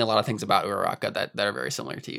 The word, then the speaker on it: Uraraka: I like Uraraka. – Yes. – Mm-hmm. – I never know if I'm pronouncing her name right, but... – Yeah, that's correct, yeah.